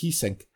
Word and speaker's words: Geesink: or was the teacher or trainer of Anton Geesink, this guy called Shozen. So one of Shobei's teachers' descendants was the judo teacher of Anton Geesink. I think in Dutch it's pronounced Giesing uh, who was the very, very first person ever who wasn't Japanese to win a gold at the Judo World Championships.